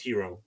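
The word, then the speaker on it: Heero: Mine is Hero.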